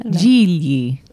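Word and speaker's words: Gigli: Jiggly.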